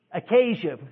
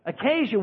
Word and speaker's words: acacia: And what they discovered was that there was a nail that went through some akashia wood, how you pronounce it? Acacia. Thank you, Mama. Acacia wood is one of the hardest woods because it grows in the desert.